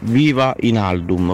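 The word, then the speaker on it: Wijnaldum: Guainaldum eccetera grazie eh, eh oh. Buongiorno, la pronuncia esatta, essendo che c'è la W come prima lettera, è Viva in Aldum, ok?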